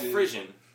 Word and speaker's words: frisson: Like the it's called frisson. I believe that's a pronunciation of it. It's F-R-I-S-S-O-N.